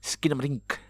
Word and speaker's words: Skinamarink: Skinamarink.